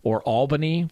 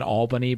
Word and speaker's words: Albany: Albany because I pronounced it Albany, and they said, well, you should know it's Albany. I was like, yeah, because there's two L's in the front of it.